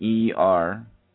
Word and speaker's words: Jeremiel: And I feel like the situation we was picking up with your, because I got a life review with you, was Archangel Jermel. I mean, mm-hmm. Jeremel. I'll spell it for you, okay? It's spelled yeah. Jeremiel.